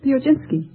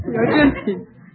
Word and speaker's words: Piechocinski: Piojinski. – Would you mind spelling it? – P-I-E-C-H-O-C-I-N-S-K-I. – Now pronounce it again. – Piojinski.